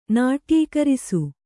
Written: ನಾಟ್ಯೀಕರಿಸು

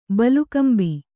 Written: ಬಲುಕಂಬಿ

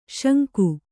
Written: ಶಂಖಿ

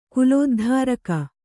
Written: ಕುಲೋದ್ಧಾರಕ

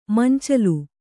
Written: ಮಂಚಲು

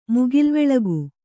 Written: ಮುಗಿಲ್ವೆಳಗು

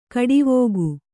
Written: ಖಡಾವಿಗೆ

